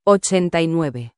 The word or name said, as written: 89